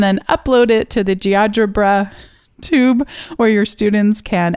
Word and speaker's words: GeoGebra: It's like algebra, but Geogebra.